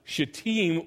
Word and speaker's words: shittim: the correct Hebrew pronunciation I found out is shittim wood, acacia wood.